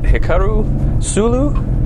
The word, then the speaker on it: Hikaru Sulu: Commander, see if I'm saying this right, Hikaru Sulu? – That's correct, yes.